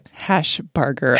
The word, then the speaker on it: Hashbarger: Hashbarger. Hashbarger. A lot of people like to say burger.